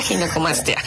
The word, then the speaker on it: Ginecomastia: Pues muchas gracias, y sí, definitivamente creo que es una, para empezar la palabra, le decía, ¿cómo se pronuncia? Ginecomastia, ginecomastia. – Sí, exacto, ginecomastia.